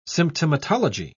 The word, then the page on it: symptomatology: British English